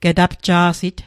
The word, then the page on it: getapja'sit: Mi'gmaq